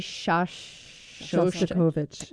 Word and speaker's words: Shostakovich: A listener named Linda suggests Dmitri Shostakovich.